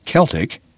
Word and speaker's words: Celtic: Because their family's Celtic, pronounced with a K, music group is a priority, and the boys have to practice.